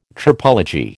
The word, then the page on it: tropology: American English